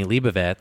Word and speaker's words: Leibovitz: And I remember she did Annie Leibovitz.